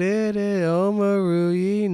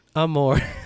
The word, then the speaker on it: Omoruyi: Omoruyi, not Amore.